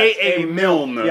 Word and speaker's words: Milne: I'm pretty sure it's not A. A. Mill. No, you said A. A. Mills. – Mills. And it's yeah, A. A. Milne.